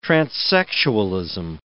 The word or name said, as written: transsexualism